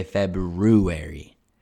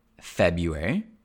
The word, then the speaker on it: February: There is an R, and it probably comes from that Latin februm. The R kind of remained in the spelling, but it disappeared in the pronunciation because generally people say February. They don't say February.